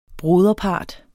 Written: broderpart

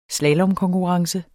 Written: slalomkonkurrence

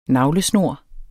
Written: navlesnor

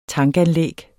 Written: tankanlæg

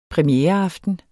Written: premiereaften

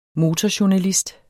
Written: MOTORJOURNALIST

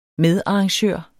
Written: medarrangør

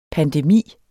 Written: pandemi

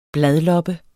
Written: bladloppe

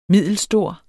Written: middelstor

